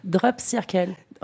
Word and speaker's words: Drop Circle: Comment on le prononce en fait ? J'ose pas trop... – Drop Circle. – Drop Circle avec l'accent, mais Drop Circle, c'est une goutte en cercle, parce que le rond, le cercle, quoi, c'est l'unité.